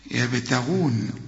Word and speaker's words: يبتغون: أرجوك ما تقولش يبتغون يابا، لا ازاي تقول يابا، يبت يبتغون، الغين هنا المفخمة يبتغون.